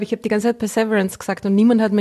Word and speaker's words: Perseverance: Perseverance. – Perseverance, okay.